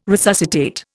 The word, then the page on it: resuscitate: Indian